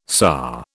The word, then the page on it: SA: British English